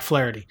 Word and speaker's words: Flaherty: Next guy up is Jack Flaherty, which I know I had just alluded to a little bit earlier when we were talking about. – You gotta say it like in an Irish accent. Flaherty.